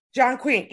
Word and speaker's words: Chongqing: Chongqing, China. 32 million. – Is it? Oh, Chongqing? – Chongqing, yeah.